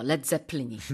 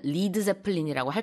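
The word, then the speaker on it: Led Zeppelin: 레드제플린이. 그런데 사람들이 발음을 리드제플린이라고 할까봐 그냥 스펠링을 LED라고 썼답니다. 자, let's have a look at some other similar expressions.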